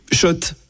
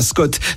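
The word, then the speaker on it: Schott: Tu viens de remporter une escarpe, Scott. – Jouer et gagner sur RMC avec Shot, la marque iconique new-yorkaise depuis 1913. Oui, Shot et pas Scott, ça fait des années qu'on prononce mal la marque.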